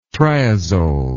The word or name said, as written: triazole